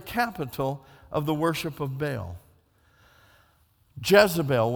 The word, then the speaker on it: Baal: and we pronounce it wrong, the pronunciation is Baal or Baal.